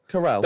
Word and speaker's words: Carell: Carell.